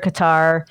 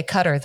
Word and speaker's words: Qatar: There's a debate on how you pronounce it. Qatar, Qatar. – I believe the people that live there say Qatar, though.